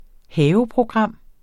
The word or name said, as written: haveprogram